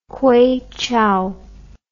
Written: kueichou

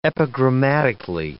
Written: epigrammatically

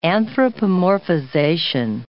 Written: anthropomorphization

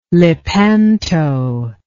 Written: Lepanto

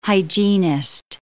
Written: hygienist

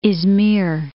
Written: Izmir

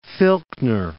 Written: Filchner Ice Shelf

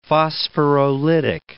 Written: phosphorolytic

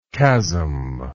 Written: chasmic

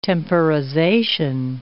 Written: temporization

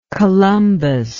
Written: columbous

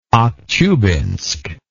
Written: Aktyubinsk

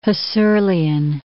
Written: Husserlian

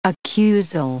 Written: accusal

